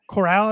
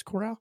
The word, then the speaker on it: Corral: Matt Corral, I believe is how you pronounce the last name, not Carroll. I think it's Corral.